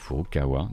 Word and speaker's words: Furukawa: Et en fait, là sur mon document, je l'avais écrit dans le mauvais sens, donc j'ai dit Furuaka. Donc Furukawa, désolé pour Shuntaro Furukawa.